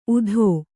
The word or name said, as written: ಊತಿ